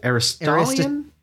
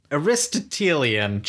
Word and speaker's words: Aristotelian: Aristotelian. – Aristotelian.